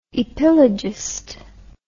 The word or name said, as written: epilogist